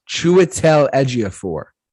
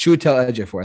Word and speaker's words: Chiwetel Ejiofor: I know how to say it. Chiwetel Ejiofor. That's how you say his name.